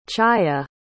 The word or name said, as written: Chaaya